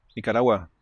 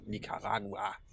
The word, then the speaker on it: Nicaragua: Nicara ich kann es nicht mal aussprechen. – Nicaragua. Nicaraguanische. – Ja, das klingt schon ein bisschen besser, ja. Nicaragua.